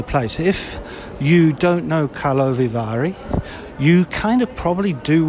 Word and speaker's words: Karlovy Vary: there you go, that's how you pronounce it, Karlovy Vary International Film Festival.